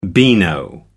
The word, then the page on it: beano: American English